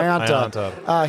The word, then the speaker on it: Ionta: We have a special guest with us, Matt Ionta. Did I say that right? – Ionta, yep. – Ionta.